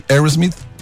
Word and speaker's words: Aerosmith: De Aerosmith, diga después de mí, Aerosmith. – A los 17 años, Steven Tyler de Aerosmith estaba viendo el papá tocar Aerosmith.